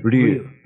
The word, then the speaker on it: Ryu: Real, real, Ryu. It's Japanese.